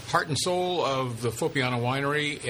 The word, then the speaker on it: Foppiano: Joining us today on California Wine Country, brought to you by Bottle Barn, with Dan Berger and Barry Herbst, it's Foppiano, right? – Correct.